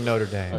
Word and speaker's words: Notre Dame: They were brothers, played at Notre Dame, which is probably why Golic Jr. went to Notre Dame. It's pronounced Notre Dame.